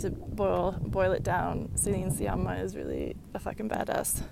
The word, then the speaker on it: Sciamma: sorry, I mispronounced her name earlier in the letters, like, Sciamma, she's like an Italian,